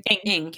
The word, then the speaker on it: Ng: Am I saying that right? – Ng. – Kate? – Ng. – Yeah. Ng. Thank you.